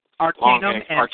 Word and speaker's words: Arkanum: Arkanum? – Yep. – Arkanum and Acid of the Gods comic.